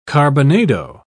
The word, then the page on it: carbonado: British English